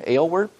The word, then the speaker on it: Aylward: Aylward, missionary in China.